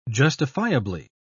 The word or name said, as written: justifiably